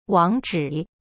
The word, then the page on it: 网址: wang zhi